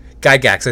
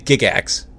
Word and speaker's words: Gygax: I think I said that right. I was saying Gigax before I knew how to say it, before I got on this interview, and he said the name correctly. Um, but hey, that's what I do.